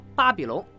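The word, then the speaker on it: Papillon: 今天咱们要说的也是一部越狱电影《巴比龙》，它最打动我的就是四个字：根据真实故事改编。这个被五花大绑的男人，就是我们的故事主人公巴比龙。这个外号源于他胸口纹的蝴蝶，蝴蝶象征自由，而蝴蝶在法语中就是念作巴比龙。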